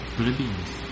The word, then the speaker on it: plebeians: Plebeians. – Plebeians? – Yeah. – Plebeians, okay. Yeah, and it's just in American, at least you put all the emphasis on the second syllable. Plebeians rather than plebeian.